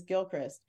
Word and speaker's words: Gilchrist: I use my given name is Gilchrist.